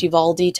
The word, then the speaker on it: Uvalde: I believe it's pronounced Uvalde, Texas.